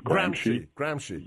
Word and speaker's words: Gramsci: Gramsci.